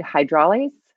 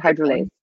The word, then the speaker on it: hydrolase: FA is fatty acid amide hydroxylase. – Yeah. – What was that? – Fatty acid. Yeah. – I don't know if I can say it right. So fatty acid amide hydrolase. Fatty acid amide hydrolase.